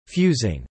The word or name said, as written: fusing